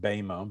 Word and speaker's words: Bema: Sometimes called the Bema, the Greek word we pronounce it Bema, but that's the word for judgment seat.